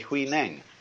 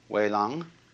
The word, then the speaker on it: Huineng: and they say Wai Lang. But this is simply the Cantonese way of pronouncing the same characters, and this is the Mandarin way. In Mandarin, Huainang becomes Wei Lang.